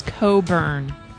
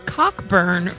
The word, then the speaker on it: Cockburn: Bruce Cockburn, not as Steve pronounced it, Cockburn earlier.